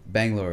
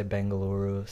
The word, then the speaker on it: Bangalore: Yeah, that's about right. Like, I mean, it's they're pronounced a couple of different ways. So some people say Bengaluru, some people say Bangalore. So it just depends.